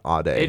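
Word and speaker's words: Ade: Well, I thought it was funny because her last name is spelled the same way as mine, but it's pronounced very different, Aide.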